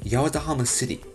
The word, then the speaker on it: Yawatahama: Yes, Yawatahamashi, Yawatahama City. I called it Hachimanhamashi. Because the kanji is exactly the same, but it's got a different pronunciation, and I didn't realize that.